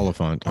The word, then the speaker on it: Olyphant: Oliphant. Is how you say it.